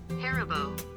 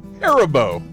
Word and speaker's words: Haribo: It's sour gummy bears, and here's how you pronounce it. I had to go to my girl on my phone. Listen up. – Yeah. – Haribo. – Oh. – Haribo. – Yeah, there you go. Now everybody knows.